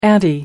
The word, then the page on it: ante: American English